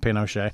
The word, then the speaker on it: Pinochet: Allende was, not Allende, Pinochet was, Pinochet, I'm going to call him Pinochet. I think that's the Spanish pronunciation as opposed to the French.